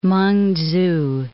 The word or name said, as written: Meng-tzu